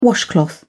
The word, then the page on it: washcloth: British English